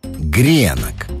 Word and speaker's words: гренок: А вот русское словесное ударение предлагает другой вариант – гренок. И никакого женского рода. Все это разноголосье можно понять, если проследить историю слова. На первый взгляд может показаться, что существительное «гренок» с ударением на последний слог русский язык позаимствовал где-то в Европе.